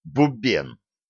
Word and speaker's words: Бубен: «Бубен», с ударением на последнем слоге.